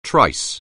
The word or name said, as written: trice